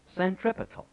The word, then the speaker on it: centripetal: That's called centripetal. That's the correct pronunciation. Centripetal. That is the bringing of the unbeliever, bringing of the outside to the center.